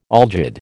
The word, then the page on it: algid: American English